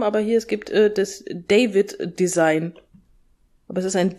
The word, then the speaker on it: Deywid: Aber ja, deswegen, also der wird genauso geschrieben, D-E-Y-W-I-D, David. – Aber gut, die Aussprache war mir jetzt neu, aber tatsächlich scheint das gar nicht mal so selten zu sein.